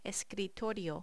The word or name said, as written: escritorio